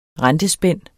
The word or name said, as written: rentespænd